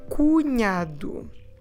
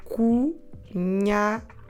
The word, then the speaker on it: cunhado: So the brother-in-law is o cunhado. Okay, maybe you've spotted that ny sound, the NH sound. Cunhado. How do we spell it? I'm Portuguese. C-U N-H-A-D-O, cunhado.